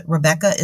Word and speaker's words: Rebekah: They can reach me on LinkedIn, Rebekah Ratliff. And Rebekah is spelled R-E-B-E-K-A-H. It is the original spelling of Rebekah.